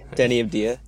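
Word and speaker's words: Deni Avdija: Denny Avdija.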